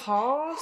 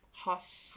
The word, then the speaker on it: Haas: Maybe we put too much emphasis on the S. Haas. Haas. Haas.